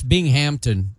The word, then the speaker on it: Binghamton: Tim would also tell you it's Binghamton.